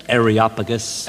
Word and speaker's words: Areopagus: Am I pronouncing right, Pastor? Areopagus, how we will pronounce it? – Areopagus. – Areopagus.